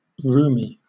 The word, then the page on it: rheumy: British English